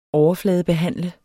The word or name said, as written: overfladebehandle